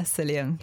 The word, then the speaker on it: psyllium: Spelt with a P, silent P. Yeah, psyllium, psyllium husk.